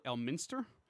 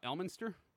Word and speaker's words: Elminster: The emphasis is on the second syllable, Elminster, not Elminster.